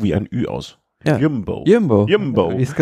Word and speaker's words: Jumbo: Der meinte so, man musst du aussprechen, U wie Ü. Jumbo.